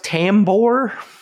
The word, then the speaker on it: Tambor: I assume it's pronounced Tambor. – Yeah, that sounds right.